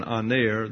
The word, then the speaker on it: aner: The Greek word is the masculine singular noun, aner. Put that up. A-N-E-R. There's that long E again, aner.